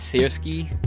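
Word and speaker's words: Syrskyi: The investigative committee of Russia is also probing Siriki. And, give me 1 second here. Alright, I get it now. It's Sirsky. That's how I'm going to pronounce it.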